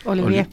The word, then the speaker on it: Olivier: Y hay que decir que la ensalada Olivier, no sé si se pronuncia así. – Sí, Olivier.